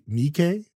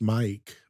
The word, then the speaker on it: Miike: And he goes from, which I also learned it's Takashi Miike is how you say it. I just learned that today. I always thought it was just Mike, but Miike, I guess that's how you say it. – So my bad for saying it wrong. I forget that there's multiple eyes in there. I just see Mike.